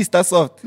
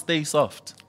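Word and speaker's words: Sta-Soft: Means Stay Soft. It's pronounced Stay Soft. – Really? – Yes. – I've been saying it wrong my whole life.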